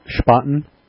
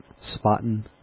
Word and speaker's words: Spaten: So that's a Spaten, not Spaten. The Germans pronounce it with a sh, so it's Spaten.